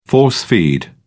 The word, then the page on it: force-feed: British English